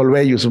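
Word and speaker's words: Olweus: Se escribe así O-L-W-E-U-S. E se pronuncia Olveyus, un tema ahí medio raro de, idioma, ¿no?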